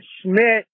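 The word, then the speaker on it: Schmit: And then Schmidt is short for Schmitty, and it rhymes with shit. So it's S-C-H-M-I-T.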